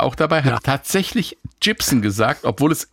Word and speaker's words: Gibson: Der legendäre Synthesizer Moog wird nicht Moog und Gibson, nicht Gibson ausgesprochen.